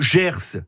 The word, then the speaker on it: Gers: Le Gers, si je ne m'abuse, on prononce bien le S à la fin.